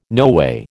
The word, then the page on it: noway: American English